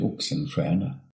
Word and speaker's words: Oxenstierna: Vi faccio sentire la pronuncia in svedese così capiamo un po' meglio questa pronuncia di una parola che si scrive in italiano Oxenstierna e invece in svedese si, si legge in un modo totalmente diverso.